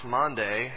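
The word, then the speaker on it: Maundy: But as I did a little research, I actually found out that I don't know everything, that it really is pronounced Monday, and that's because it's a Latin word, and the Latin word means a mandate or a command.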